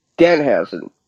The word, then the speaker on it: Danhausen: I'm going to stop you right there, and we're gonna say this correctly. One syllable, Danhausen.